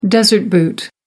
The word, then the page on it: desert boot: American English